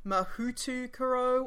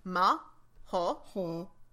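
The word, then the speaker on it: Mahoutokoro: Yeah, the actual Japanese pronunciation, because I did like a little bit of Japanese in school. I know enough to know the alphabet. It should be Mahotokoro.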